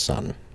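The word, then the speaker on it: Sunn O))): By the way, not to get big brained on you, but it's just pronounced Sun.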